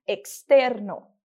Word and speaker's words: externo: Nosso último exemplo é com a palavra externo, que significa externo, e que vai ser pronunciado com o x, ficando externo, externo.